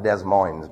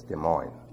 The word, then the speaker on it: Des Moines: It's not Des Moines, by the way, it's Des Moines. We had a teacher here years ago that pronounced it Des Moines. Des Moines. De Bear, see, Des Moines, it's like that.